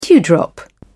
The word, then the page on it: dewdrop: British English